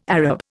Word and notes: Arab: Indian

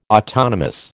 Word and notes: autonomous: American English

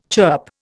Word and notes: chirp: Indian

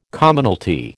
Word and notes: Commonalty: American English

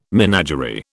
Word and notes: menagerie: British English

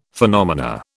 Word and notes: phenomena: British English

